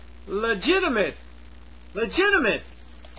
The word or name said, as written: legitimate